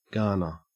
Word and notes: garner: Australian English

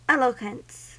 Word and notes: eloquence: American English

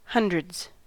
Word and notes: hundreds: American English

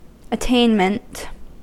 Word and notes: attainment: American English

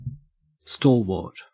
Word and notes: stalwart: Australian English

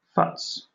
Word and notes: futz: British English

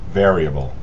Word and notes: variable: American English

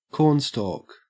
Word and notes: cornstalk: Australian English

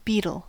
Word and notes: beetle: American English

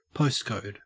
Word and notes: postcode: Australian English

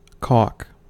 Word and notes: caulk: American English

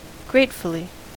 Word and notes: gratefully: American English

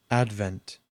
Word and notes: advent: American English

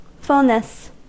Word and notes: fulness: American English

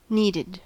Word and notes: needed: American English

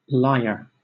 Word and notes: lyre: British English

